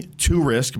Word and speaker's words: Tourisk: So Jeremy Tourisk, my last name is spelt Tourisk, but you risk nothing when you work with me.